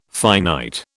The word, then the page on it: finite: British English